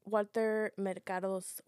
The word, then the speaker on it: Walter Mercado: Oh wait, also our Walter Mercado's. Look at me, I'm butchering this person's name. – Walter Mercado. – Thank you.